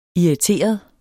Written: irriteret

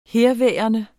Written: herværende

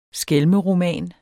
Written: skælmeroman

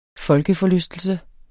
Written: folkeforlystelse

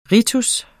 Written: ritens

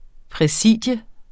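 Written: præsidium

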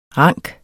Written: ranke

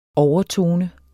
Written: overtone

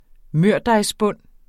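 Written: mørdejsbunde

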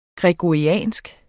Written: gregoriansk

